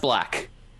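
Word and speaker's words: 6LACK: The Pink Phantom by the Gorillaz featuring Elton John and Six Lack. – It's actually pronounced Black.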